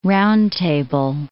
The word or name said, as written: round table